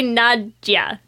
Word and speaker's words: Nadja: I think it's supposed to be Nadja. – Oh, Nadja. – That's at least how the first guy that she kills pronounces it. When she's at the runway, whatever modeling show. The first guy who walks in kind of says it Nadja, and then nobody else ever seems to know how to pronounce it after that.